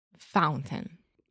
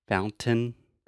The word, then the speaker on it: fountain: Yeah, that is actually what I call a stop T. So most of the time, I would not say fountain.